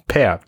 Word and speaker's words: Pear: Pear Boost, nicht Bär. Pear, die Birne. – 32 ähm, Milligramm pro Milliliter Koffein, äh, äh, wie ein ausgerippter Bär. Ähm, ja. – Ich habe ja früher immer gedacht, das wird Pier ausgesprochen, wie der Pier.